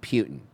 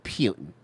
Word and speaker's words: Putin: First off, I was told by Mark Levin it's pronounced Putin. Putin.